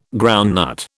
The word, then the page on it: groundnut: British English